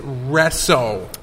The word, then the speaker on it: Rezo: It's Rezo. – Rezo. – Pronounced like it's spelled with an S, not a Z.